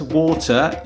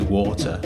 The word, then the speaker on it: water: I say water and you say water. But, you know, it's a bit weird that you don't write it W-A-D-E-R because it's a T in the middle of the word that you're not pronouncing.